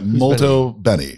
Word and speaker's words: molto bene: Molto bene. – But you have to pronounce it like that. Molto Benny.